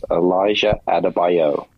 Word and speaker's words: Elijah Adebayo: His name is Elijah Adebayo.